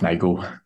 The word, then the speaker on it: Nagel: Is that how you say your last name? – Yeah, close enough, Nagel.